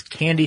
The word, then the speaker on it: Kandi: And you know what's really clever about this is that it's Candy Technologies, spelled K-A-N-D-I, but pronounced candy, and they call it the candy machine.